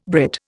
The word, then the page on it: Brit: Indian